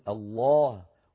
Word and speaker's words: الله: تغلظ العرب اللام بإجماع في لفظ الجلالة، الله جل جلاله. إذن كل القبائل العربية، كل القبائل العربية لما تنطق لفظ الجلالة تقول الله، ولا أحد من العرب البتة يقول الله بسم الله، مو بسم الله، الله هكذا، لا بد أن يقول الله مفخمة، إلا إن سبقت بكسرة أو بكسرة فإنه عندئذ ينطقونها مرققة للام من أجل مجانسة الكسرة.